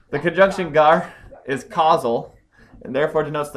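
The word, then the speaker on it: gar (γάρ): The conjunction gar, pronounced gar, is causal.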